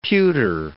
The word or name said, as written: pewter